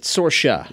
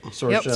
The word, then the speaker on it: SORCIA: SORCIA. – Yep. – Not SORCIA? – Yep. – SORCIA.